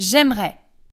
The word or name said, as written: j'aimerais